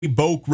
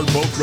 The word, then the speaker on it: Bouck: And I think I'm pronouncing the road right there, B-O-U-C-K, Boak Road.